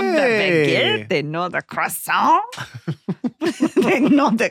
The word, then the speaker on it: croissant: No, because a croissant. – It's pronounced croissant. – Okay. – Croissant. We just lost all our French listeners.